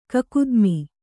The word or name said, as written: ಕಕುದ್ಮ